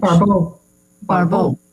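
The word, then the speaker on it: Barbault: Barbeau. – Yeah, thank you. Right pronunciation, Barbeau, brought to general attention.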